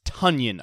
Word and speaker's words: Tonyan: No, it's Tonyan, apparently. Matt Schneidman over at The Athletic broke the news. Packers PR, he says, has alerted us that Robert's last name is pronounced Tonyan, as in rhyming with Funyan.